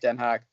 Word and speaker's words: Den Haag: Well, it's Den Haag, isn't it? That's what. Well, yeah, it's pronounced Den Haag.